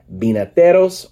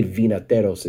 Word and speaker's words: vinateros: So the V in Spanish is pronounced like a B, so it's not vinateros. I mean, if you say vinateros, it's fine, but it's vinateros, and it's like has to do with wine, winemakers, right?